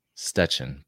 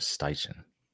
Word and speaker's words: Steichen: Shane Steichen. It could be pronounced Stechen, but we're going to go with Steichen. Eagles offensive coordinator for the last 2 years.